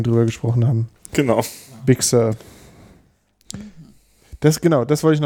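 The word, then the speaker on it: Big Sur: Big Sir. Big Sir. – Und beides equally betont.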